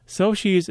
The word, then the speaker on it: Xochi: Another place in Detroit you can see some ofrendas this weekend is Soshi's Gift Shop. Soshi's is spelled X-O-C-H-I.